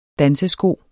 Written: dansesko

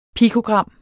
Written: pikogram

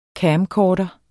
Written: CAMCORDER

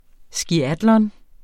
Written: skiatlon

